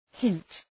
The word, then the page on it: hint: hınt